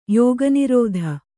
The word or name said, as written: ಯೋಗನಿರೋಧ